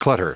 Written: clutter

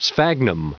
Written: sphagnum

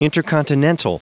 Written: intercontinental

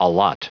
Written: allot

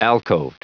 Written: alcoved

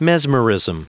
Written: mesmerism

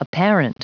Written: apparent

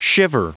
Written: shiver

